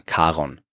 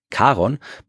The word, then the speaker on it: Charon: Christie, der wollte ihm den Namen Charon geben, vor allem, weil er damit seiner Frau Charlene eine Freude machen wollte, weil ihr Spitzname klingt ein bisschen so wie die amerikanische Aussprache von Charon.